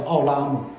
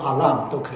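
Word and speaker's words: 奥 拉 姆 (עולם): 那永远这个字呢，它从中文的发音来看就是奥拉姆啊，就是奥拉姆啊，或者阿拉姆都可以啊。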